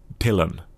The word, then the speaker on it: Dylan: Ja, där då så uttalar jag ju hans efternamn rätt. Dylan. – Ja. – Men jag säger ändå Bob. Så jag säger Bob Dylan.